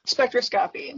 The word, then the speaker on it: spectroscopy: Spectroscopy.